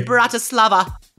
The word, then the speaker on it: Bratislava: Well, enjoy the rest of your night there in Bratislava. – I shall. – Say that right? – Okay. Yes, you did.